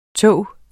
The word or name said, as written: tog